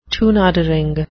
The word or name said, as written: toenadering